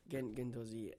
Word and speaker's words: Guendouzi: Yeah, Guendouzi.